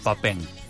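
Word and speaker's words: Papin: Y no sé si no hizo goles un delantero, un 9 joven en ese momento, se llama Papen. ¿Lo tenés? – Con I se escribe, Papín, ¿no? – Creo que sí. – Pero vos lo estás pronunciando bien, pero...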